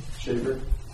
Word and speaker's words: Schafer: Schaefer. – Schaefer. – S-C-H-A-F-E-R. – A-F-E-R.